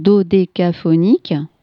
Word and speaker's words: dodécaphonique: Et du coup, en fait, toute sa musique, hein, a donné naissance, alors qu'on appelait la musique dodécaphonique. Je me suis dit que j'essayais de pas l'écorcher, ce mot. – C'est bien, oui, t'as bien séparé chaque.